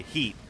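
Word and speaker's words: H.E.A.T.: It's spelled H period E period A period T period, but they don't say it like that. As far as I know, they say Heat.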